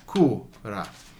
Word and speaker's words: cura: So in this case, C-I is a chi sound, whereas C-U is a hard C. Cura.